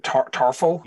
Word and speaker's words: Tarful: Yeah, so if I remember correctly, you are seeking out, how do I pronounce it, Tarful? – Yeah.